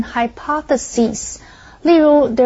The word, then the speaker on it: hypothesis: 我对于写电脑软体一点概念都没有。继续我们看 hypothesis 这个名词 ,HYPO 这个字首有以下或之下的意思。thesis 是指理论 ,hypothesis 未成熟的理论就是指假设或假定。注意它的重音是在 PO 这个音节念成 hypothesis。注意它的复数形是字尾的 SIS 变成 SES, 而且后面的尾音变成长音的 E 念成 hypothesis。例如 ,there have been many different hypotheses about the members of the new cabinet.